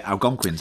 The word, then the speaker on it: Algonquians: What is that word? Algonquians. – Algonquians? – Yeah, Algonquians, that's good.